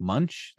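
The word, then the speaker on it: Moench: In your Moon Knight episode, you pronounced Doug Monek. I pronounced it as Doug Monek when it's pronounced more like Munch. The N isn't in the place where it would sound like you have pronounced it.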